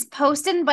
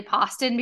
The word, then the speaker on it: Poston: It's Poston, but I Poston. – understand why people say Poston because it looks like Boston.